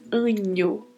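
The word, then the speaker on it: año: Então nós falamos anho, anho, como se fosse escrito com o NH.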